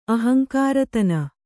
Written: ಅಹಂಕಾರತನ

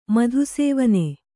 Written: ಮಧುಸೇವನೆ

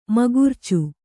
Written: ಮೂಗುರ್ಚಿ

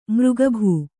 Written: ಮಾರಿಕಬ್ಬೆ

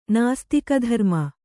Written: ನಾಸ್ತಿಕಧರ್ಮ

